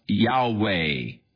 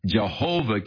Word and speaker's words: Yahweh: So the general consensus of opinion is that the name was to be pronounced Yahweh. But somewhere along the line, the pronunciation of Jehovah came along, or Jehovah, and it has become more popular, but Yahweh is probably the correct pronunciation.